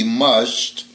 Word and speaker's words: musht: It is spelled M-U-S-H-T, mushed, and it's a type of fish that swims and feeds in shoals and has a comb-like dorsal fin.